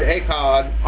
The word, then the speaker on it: Echad: The word is from the Hebrew E-C-H-A-D, E-C-H-A-D. It's to be pronounced E-K-H-A-W-D, Echad.